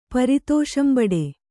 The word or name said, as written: ಪರಿತೋಷಂಬಡೆ